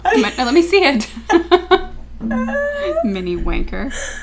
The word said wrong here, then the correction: Minnewanka: Minnewanka. – Sure. – Yeah. – Minnewanka. Is a mountain with a high, sharp peak shaped like a tower.